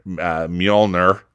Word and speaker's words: Mjolnir: And she is drawn to go to Asgard, which is now on Earth, she ends up wielding Thor's hammer, Mjolnir.